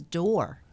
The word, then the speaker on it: Dorr: My last name is Dorr. It's spelled D-O-R-R.